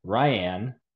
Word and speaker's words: Riane: Dan and I are joined by Riane, pronounced like Diane, who is coming off of her Miss America win.